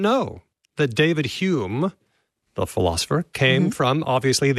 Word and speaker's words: Home: Mr. Speakeasy might like to tell us how they get to pronounce home as whom, as in Alexander Frederick Douglas Hume.